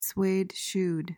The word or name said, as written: suede-shoed